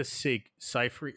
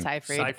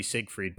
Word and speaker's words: Seyfried: Amanda Seyfried. How the fuck do you say it? It is Seyfried. I said it wrong. – It looks like it's supposed to be Siegfried, but it's Seyfried.